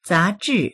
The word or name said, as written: za2zhi4